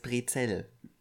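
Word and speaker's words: Brezel: Wenn hm. was anderes sagt, nee, es heißt Brezell.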